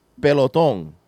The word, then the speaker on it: Pelotón: So Peloton is funny because, as you know, in Spanish, it's spelled the same way, but you would pronounce it Pelotón, right?